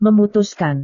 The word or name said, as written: memutuskan